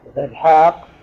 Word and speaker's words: إسحاق: إسحاق فيها ألف نطقاً لا كتابة.